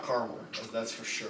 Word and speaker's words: Carmel: It says caramel is pronounced with 3 syllables. Caramel is a city pronounced with 2 syllables. – Caramel Mountain. – Yeah. – What am I going for? – That's spelled C-A-R-M-E-L.